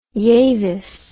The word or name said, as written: yavis